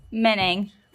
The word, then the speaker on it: Moening: Moening. – Moening? – Yeah. – Okay. – Didn't see that one coming. – Me neither. Moening. I thought it was Moening forever. So the O is silent.